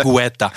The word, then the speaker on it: Guetta: Der idealisiert einen David Goethe. So, mit seinen, habe ich gut ausgesprochen gerade, glaube ich, Guetta. – Guetta.